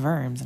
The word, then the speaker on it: Worms: And they're like, no, it's pronounced Diet of Worms. And I'm like, ugh, German.